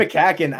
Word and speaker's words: McCaughan: A lot of comments about the Darren McCaughan. Is that how you say it? – Darren McCaughan, our guy.